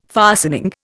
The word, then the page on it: fastening: Indian